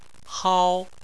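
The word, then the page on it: hao: tone 1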